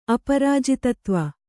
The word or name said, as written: ಅಪರಾಜಿತತ್ವ